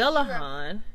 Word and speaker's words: Dullahan: You know, there is a strong chance that it comes from Ireland, where stories of a similar creature called, I hope I'm going to pronounce this right, the Dullahan, or yeah, the Dullahan, is that right? – Yeah, you pronounce that right.